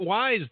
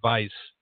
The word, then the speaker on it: Weiss: It's not Wise, though. W-I-S-E. – W-E-I-S-S, I think. – It's pronounced Vice.